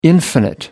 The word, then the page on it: infinite: American English